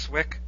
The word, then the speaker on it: Cwik: Your last name is spelled C-W-I-K, and you're pretending like it's pronounced Swick? – That's what they tell me.